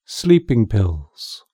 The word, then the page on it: sleeping pills: British English